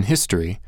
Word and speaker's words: history: We've already played with disappearing consonant sounds, no B sound at the end of bomb, and displaced vowel sounds, no O sound in history.